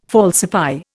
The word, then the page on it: falsify: Indian